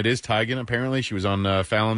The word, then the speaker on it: Teigen: Yeah, it's a TMZ thing, and like you said, all the news media are saying her mother finally told her, it's pronounced Tegan. – It is Tegan.